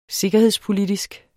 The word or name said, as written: sikkerhedspolitisk